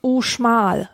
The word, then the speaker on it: Uxmal: so eine Ruinenstätte Uxmal. – Utschmal. – Uschmal wird's ausgesprochen.